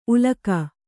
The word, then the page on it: ಉಲಕ: ulaka